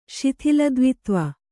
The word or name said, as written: ಶಿಥಿಲದ್ವಿತ್ವ